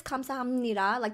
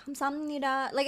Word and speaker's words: 감사합니다: So it is 감사합니다. Like, that's the way to say it. But people do shortcuts in Korea. So it's like 감사합니다. Like, it's like mushed together.